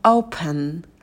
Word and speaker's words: open: Далее слово open. Open. Что здесь? Во-первых, в начале у нас дифтонг L, его произносите почетче, то есть это не open, да, просто O, а open. Open. И этот дифтонг прям проговариваете.